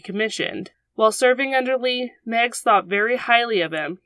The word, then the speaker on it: Meigs: A Union officer named Montgomery Meigs, I think that's how you say it, I even looked up the pronunciation, it's spelled M-E-I-G-S, but I think it's Meigs.